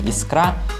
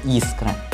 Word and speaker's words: искра: Фраза очень душевная, но ударение поставлено неверно – Хотя наиболее привычен вариант произношения «искра», правильно является «искра». Ставить ударение на первый слог следует как в литературной речи, так и в разговорной.